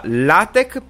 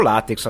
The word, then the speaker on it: LaTeX: LaTeX per l'ingegnere o LaTeX a seconda di come lo pronunciate, ho visto che la Wikipedia riporta entrambi.